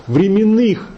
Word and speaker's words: временных: Как раз Лихачев доказывает, что временных было ударение в Древней Руси. Не было такого ударения в древнерусской литературе и в словесности.